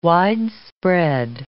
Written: widespread

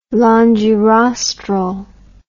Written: longirostral

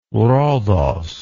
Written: Rhodos

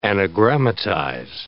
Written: anagrammatize

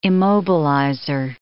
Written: immobilizer

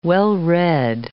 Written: well-read

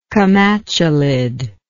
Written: comatulid

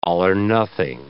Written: all-or-nothing

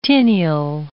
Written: tineal

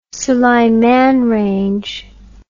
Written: sulaiman range

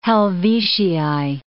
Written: Helvetii

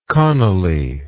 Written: connolly

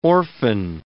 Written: orphan

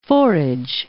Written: forage

